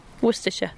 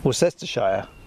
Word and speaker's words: Worcestershire: Worcestershire. – Worcestershire. Yeah, for our American listeners, it's spelt Worcestershire, but it's not pronounced.